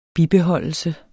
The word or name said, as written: bibeholdelse